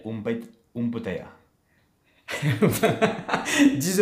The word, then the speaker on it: bouteille: bouteille d'eau